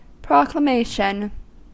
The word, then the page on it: proclamation: American English